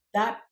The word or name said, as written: thad